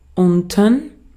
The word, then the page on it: unten: /ˈʊntn̩/